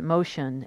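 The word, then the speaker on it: Motian: And besides all that, people said Motion so much I thought, beep it, I'll pronounce it like that. Modian, pronounced Motion, sounded more American. I changed the pronunciation, but not the spelling.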